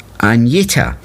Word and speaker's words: Agnetha: By 1972, Agnetha, pronounced Agneta, had married Bjorn, and Benny was living with Anna Fried, known as Frida.